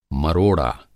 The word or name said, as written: मरोड़ा